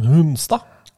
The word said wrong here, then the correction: Hunstad: Han uttaler ikke navnet sitt sånn som jeg gjorde det nå. Hunstad. Han uttaler det helt vanlig.